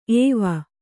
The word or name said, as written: ಏವು